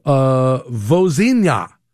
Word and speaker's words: Vozinha: Vozinha. V-O-Z-I-N-H-A.